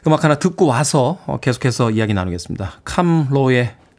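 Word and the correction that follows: Camp Lo: 음악 준비했습니다. 캠로라고 읽어야죠. 캠로.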